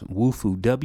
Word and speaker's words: Wufoo: If you've done the survey that I asked you to do at the beginning of the podcast, you'll see that is Waifu. And Waifu is pretty good. I'm happy with them so far. And I may even be saying the name wrong. It's Wufu, WUFOO.